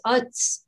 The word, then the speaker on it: Quds: And then in Arabic, it's Uds, but it's hard to say. So it's spelled Q-U-D, like D-S, and means Jerusalem, actually.